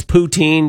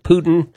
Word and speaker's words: poutine: She also says toque, bunny hugs, poutine, because everybody pronounces it Putin.